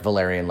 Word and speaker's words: Valyrian: Now that's spelled V-A-L-Y-R-I-A-N. Valyria, Game of Thrones, blah, blah, blah. Okay, it's not like Valyrian like the herb, but it sounds the same, Valyrian.